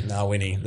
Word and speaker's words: Ngawyni: Ngawyni. – The Ngawyni Stakes.